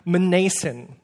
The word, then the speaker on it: Mnason: The passage in verse 16, some of the disciples from Caesarea accompanied us and brought us to the home of Mason or Nason. And actually in Greek, whenever you have those two consonants together, you actually pronounce both of them. So it would be pronounced Mnason, where we were to stay.